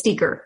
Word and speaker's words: Steiger: You know, so like I hope I'm saying his name right. Ed Steiger. – I think it's Steiger, I believe. – Steiger. I'm sorry. Yeah. Steiger.